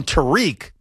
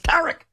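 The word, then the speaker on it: Tarik: And some people call him Tariq, but it's not pronounced Tariq. It's pronounced Tariq.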